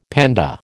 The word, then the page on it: panda: American English